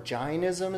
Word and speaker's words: Jainism: So if you've heard of Jainism, or Jainism as it's pronounced,